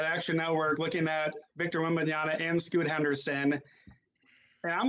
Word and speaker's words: Wembanyama: Victor Wembanyama. I am sure I'm butchering that, and I apologize. I'm trying to get it. Did I get it? Okay. – Yeah. Right. Yeah, you definitely got it.